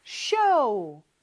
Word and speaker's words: show: 근데 발음은 show. 우, 이렇게 끝나야 돼요. 그냥 show가 아니고.